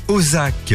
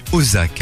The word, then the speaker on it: O2ZAK: Voici Ozak, l'application en fait qui révolutionne l'achat de places de cinéma. L'appli Ozak, alors ça s'écrit O2ZAK, nous permet de réserver nos séances donc du ciné avec des réductions allant jusqu'à 50%.